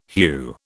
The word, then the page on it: hue: British English